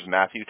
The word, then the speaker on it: Matthew: It's M-A-T-H-E-W? – M-A-T-T-H-E-W. – Okay, gotcha. That's how I spell it. My first name is Matthew.